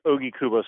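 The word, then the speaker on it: Ogikubo: It's actually pronounced OG Kubo Station.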